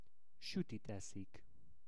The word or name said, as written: sutit eszik